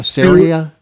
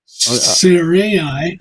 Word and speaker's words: Sarai: S-A-R-A-I, Sarai? – Yeah. Sarai.